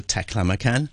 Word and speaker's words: Taklamakan: I guess this is the prospective cover of Walking the Taklamakan.